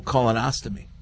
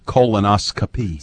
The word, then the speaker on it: colonoscopy: Well, when I turned 50, I had to have the whole colonoscopy. – It's not how you say it, but I know what you're talking about. – What is it? – Colonoscopy.